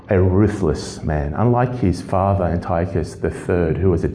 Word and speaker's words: Antiochus: his name is Antiochus, or Antiochus. Oh, I can't pronounce it. Let's call him Antiochus. That's the word, Antiochus. Some will call him Antiochus. Antiochus the Fourth.